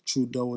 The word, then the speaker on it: Trudoe: Trudeau is spelled T-R-U-D-O-E, not the regular Trudeau, like the Prime Minister's name.